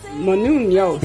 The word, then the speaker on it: Menounos: Maria Mahu? – Menounos. Did I not say it right? – Maria Menounos.